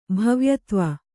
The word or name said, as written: ಭವ್ಯತ್ವ